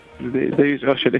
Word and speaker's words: Oyelowo: Tenemos a David Oyelowo. David Oyelowo, está bien pronunciado.